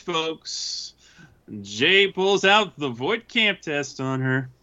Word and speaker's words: Voight-Kampff: The Voight-Kampff test. – Yeah, that's how it's pronounced.